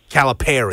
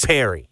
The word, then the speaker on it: Calipari: That's what you emphasize. – Calipari. – Yeah, so Calipari. But sorry, Perry. – Cal would be in all uppercase because that's the syllable that you pronunciate the most.